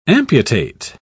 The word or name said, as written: amputate